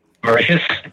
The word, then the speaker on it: Mauritius: Mauritia, I believe. It's a country in South somewhere. How did you pronounce it? – Mauritius. – Yeah, that. Yep.